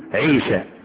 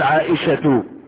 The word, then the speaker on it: عائشة: بعض الناس ينطقونها خطأ، يقولون عيشة فاطمة، هذا عيب، لماذا لا نسمي الاشياء بأسمائها؟ تقول عائشة فاطمة فاطمة، يقول لك اقول فاطمة والناس يسخرون مني، هيكلمنا بالنحوي وهذا خطأ، وسيحدثنا بالنحوي، لكن النحوي دي بفتح الواو النحوي هذا خطأ.